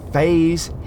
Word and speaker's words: Fahy: And he's from Fays Hill, County Clare. – Yeah. – So this place is spelled F-A-H-Y, Fay.